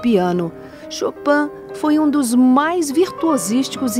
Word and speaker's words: Chopin: Ao gravar os famosos 24 prelúdios do Opus 28 de Frédéric Chopin, pronúncia original polonesa para a versão francesa, Frédéric Chopin,